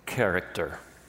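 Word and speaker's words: Character (χαρακτήρ): This is the Greek pronunciation. Character. Character.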